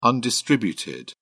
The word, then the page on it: undistributed: British English